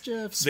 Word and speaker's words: GIF: The creator says it's GIFs. – A lot of people who create things are wrong about their things. It's graphics exchange. It's graphics interchange format. Graphics is a hard G. GIF.